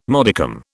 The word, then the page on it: modicum: British English